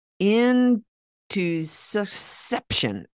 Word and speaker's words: intussusception: It's called intussusception.